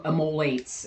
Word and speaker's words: immolates: self emulates em- immolates is how it's actually pronounced